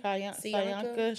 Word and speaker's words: Cyanka: C-Y-A-N-K-A. – Kayanka.